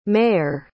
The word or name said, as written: meir